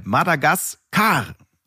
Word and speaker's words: Madagaskar: Betonung auf der letzten Silbe. Madagaskar.